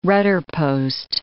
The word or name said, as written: rudderpost